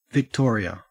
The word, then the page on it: victoria: Australian English